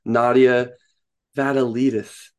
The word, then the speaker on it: Nadia Vatalidis: We are joined by the absolutely wonderful Nadia Vatalidis.